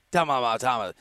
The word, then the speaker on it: Tomljanovic: There's one extra syllable, I feel like, in her name. – Damn it, you're right. – It's like Tomljanovic. There's just something in the middle there.